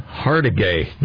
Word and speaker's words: Hargitay: Oh, it's Mariska Hargitay or how do you pronounce her name? – Hargitay. Nice one. – Hardiger.